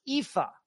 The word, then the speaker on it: Aoife: Aoif. Hello once again from Edmonton. It's always so enjoyable listening to you. Oh, there we go. It's pronounced Aoife. Pronounced Colleen. Aoife, Colleen. Both Irish names meaning beautiful daughter.